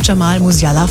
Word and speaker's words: Jamal Musiala: Joshua Kimmich vom FC Bayern und auch Jamal Musiala vom FC Bayern, richtig? – Ey, sogar richtig ausgesprochen.